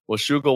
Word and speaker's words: Washougal: I'm going to try to pronounce this, Washougal, Washington. Is that right? – That's right, man. – Washougal, Washington.